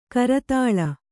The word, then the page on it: ಕರತಾಲ: kannada